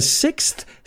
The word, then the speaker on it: sixth: Americans say sixth. So la palabra sick con S y luego sonido Z. Sixth. Sixth. Los británicos dicen sixth. O sea, dicen enfermo sin la S con el sonido TH.